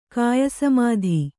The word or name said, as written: ಕಾಯಸಮಾಧಿ